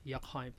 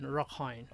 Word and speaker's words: Rakhine: So they call the people call them as Rakhine people. – How do you pronounce? – R-A-K-I-N, Rakhine. – R-A-K-I-N.